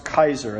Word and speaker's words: Keyser: Remember, Kaiser isn't spelled like the roll. I've done the Kaiser roll a few times when I've tripped, but it is spelled K-E-Y-S-E-R, like Kaiser, but pronounced Kaiser, a Dutch spelling of a German name, the German equivalent of Caesar.